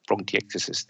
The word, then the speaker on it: bronchiectasis: And bronchitis, is it? Or I'm not sure I said that right, have I? – Bronchiectasis, there you go.